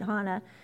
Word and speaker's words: Hana: I was trying to find something in my journal for sweet Hana. My youngest son's girlfriend is here, and I adore her. It's Hana spelled Hannah, like Hannah, but it's pronounced like the road in Hawaii.